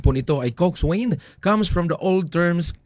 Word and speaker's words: coxswain: In modern rowing, the coxswain is the strategist, navigator, motivator, and field commander of the crew, mga kapuwersa. Ang pagbasa po, ng cox ay coxswain, pero pag pronounce po nito ay coxswain.